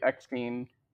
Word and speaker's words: Eckstein: Uh, we have Rosario Dawson as Ahsoka, which a lot of people were against um, her playing Ahsoka instead of Ashley Ecken. How do you pronounce her last name again? I'm sorry. Uh, I think it's Ashley Eckstein.